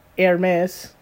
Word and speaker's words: Hermes: Hermes. Because the H is silent.